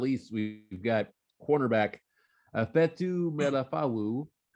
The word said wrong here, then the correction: Ifeatu Melifonwu: It's all right. Ifeatu Melifonwu. Ifeatu Melifonwu.